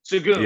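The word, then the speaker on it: Şengün: Sengun.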